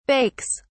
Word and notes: bakes: British English

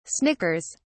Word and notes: snickers: British English

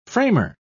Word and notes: framer: British English

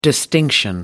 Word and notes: distinction: American English

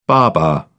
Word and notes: baba: British English

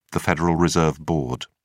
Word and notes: federal reserve board: British English

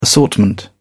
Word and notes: assortment: British English

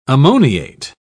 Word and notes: ammoniate: British English

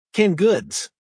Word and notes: canned goods: American English

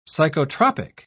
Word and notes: psychotropic: British English

